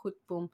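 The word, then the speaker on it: Grootboom: Subramani is quite famous, but South Africans pronounce it Groepboom.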